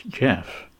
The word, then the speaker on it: Geoff: That's G-E-O-F-F. To me, that name is obviously pronounced Jeff. That's one of the variations of how it's spelt in the UK, at least.